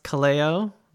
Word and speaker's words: Kaleo: And just to check, is the band pronounced Kaleo? Because Taylor. – Yes.